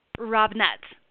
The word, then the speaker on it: Robnett: Robnett.